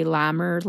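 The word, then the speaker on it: Lehmer: But my main source probably was the book, The Day the Music Died by Larry Lamer, spelled like Dahmer with an L.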